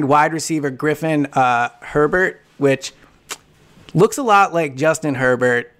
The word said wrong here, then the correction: Hebert: but it's not. It's Hebert. So that was a mispronunciation on my part.